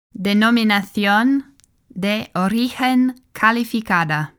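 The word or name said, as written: Denominación de Origen Calificada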